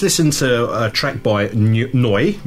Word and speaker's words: Neu: Next on the list of tracks that we're going to play is by a band called Noi. Did I pronounce that right? – I think so, yeah, Noi. That's about, I'm talking bollocks again, but yes, I think that was the right pronunciation.